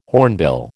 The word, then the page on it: hornbill: American English